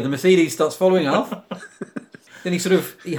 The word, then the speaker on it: Mercedes: Sorry, I've just got to correct your pronunciation there. You said Mercedes. – Yeah. – Well, according to Alf, it's Mercedes.